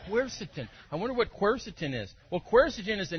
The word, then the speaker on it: quercetin: The key ingredient is quercetin, pronounced quercetin.